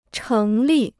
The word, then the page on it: 成立: chéng lì